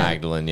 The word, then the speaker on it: Magdalene: Is that how you pronounce that? Is it Magdalene? – Yeah. Magdalene, yeah.